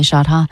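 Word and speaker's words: Chadha: And there is a conference featuring Shelly Shadha. Am I pronouncing her name right? Dr. Shelly Shadha of the World Health.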